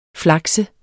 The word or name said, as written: flakse